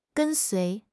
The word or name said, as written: 跟随